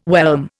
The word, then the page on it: whelm: Indian